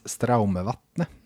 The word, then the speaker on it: Straumevatne: Eh, vi hoppar vidare till Gabriel Jansson, Axel Jansson och Elias Straumevatne. Mm. Är det, var det godkänt norskt uttal? – Det var rätt bra gjort ja, faktiskt.